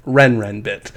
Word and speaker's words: Renrenbit: Rarinbit, the top Chinese crypto over-the-counter trading firm. Renrenbit? Yeah, that's how you say it. Renrenbit.